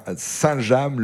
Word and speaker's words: Saint-James: Et on prononce bien Saint-James, le nom de cette commune.